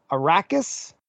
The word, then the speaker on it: Arrakis: In Dune, while staying on the desert planet Arrakis, hope I'm pronouncing that correctly.